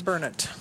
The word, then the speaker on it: Burnet: And this little town called Burnett was designated the center of... Wait, how's that pronounced? – Burnett. – How's it spelled? – B-U-R-N-E-T.